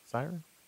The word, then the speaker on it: Psyren: It's spelled a little bit differently. It's P-S-Y-R-E-N, right? Siren? Yeah, P-S-Y-R-E-N. And the character, it's a female character, and she has what it looks like a whip, but it turns into a sword?